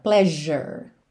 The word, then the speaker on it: pleasure: Aqui, atenção a essa palavra pleasure, que é escrita com S, certo? P-L-E-A-S-U-R-E. O E final não é pronunciado, já tá pintadinho aí de cinza. E esse S do meio tá pintadinho de verde, para você lembrar, ele vai soar como um J, pleasure, pleasure ok?